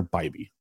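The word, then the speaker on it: Bibee: We're waiting for Bibby. I always mispronounce it. Bibby. Sorry, it's Tanner Bibby. That says Bibby. My apologies.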